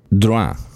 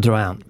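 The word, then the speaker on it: Drouhin: Drouin, tror jag. – Drouin. Inget P. Drouin.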